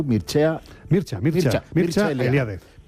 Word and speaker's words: Mircea Eliade: El rumano, ¿cómo lo pronuncias tú? – Mircea Eliade.